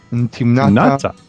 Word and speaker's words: Timnata: It's written Timnata. – Timnata. – Yeah, Timnata. But Timnata, that's what's called a directive suffix.